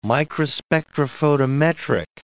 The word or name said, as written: microspectrophotometric